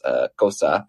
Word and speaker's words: Xhosa: And then one of the other Zulu, he says the most spoken at home, but the other one that's commonly spoken is Xhosa. I'm not pronouncing it correctly because that involves like the clicks of the tongue, which are really difficult.